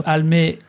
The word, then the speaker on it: Almé: Almé, comme ça se prononce, avec un E, c'est important.